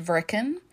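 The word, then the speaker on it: Wrechen: Irma was born in a pretty little German village called Vrecken, but it's spelt with a W, but it's pronounced Vrecken, in the region of Mecklenburg, Germany.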